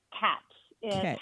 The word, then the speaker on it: QATCH: QATCH. QATCH with a Q. Some okay. People say QATCH, which it is not QATCH.